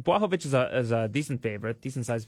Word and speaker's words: Błachowicz: And I've had people ask why I pronounce it Błachowicz, and that's because that's how he told me it was pronounced. – Błachowicz.